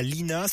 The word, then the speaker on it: Linas: Et à Lina, ça s'écrit L-I-N-A-S, vous récupérez la francilienne et ainsi vous pourrez poursuivre votre trajet en direction d'Evry-Courcouronnes de l'autoroute A6.